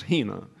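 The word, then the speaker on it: hina (ἵνα): There is an important word in John 3:16, a conjunction pronounced hina, and is generally translated in English by the word that, which in this context introduces both a purpose and a result.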